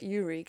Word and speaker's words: Yuri: Yuri came up.